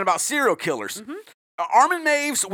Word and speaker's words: Meiwes: Now, I will say, you're going to see his name is spelled Meiwes. It looks like it's Mewies. He's German. It is pronounced Armin Maves.